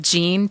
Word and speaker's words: Jean: So my name is spelled Jean, J-E-A-N, Latu, L-A-T-U.